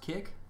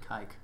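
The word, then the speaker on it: Kik: Is it pronounced kick? – Yeah. – I always pronounced it kike.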